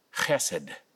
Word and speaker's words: chesed: It's the word chesed. It's spelled in English as H-E-S-E-D with a strong ch at the beginning.